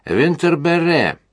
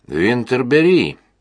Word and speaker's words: Винтерберри: «Мадемуазель Винтерберре». Именно так, с ударением на последнем слоге. Мэй оторопела, а нахал подошел уже совсем близко и повторил. «Мадемуазель Винтерберри».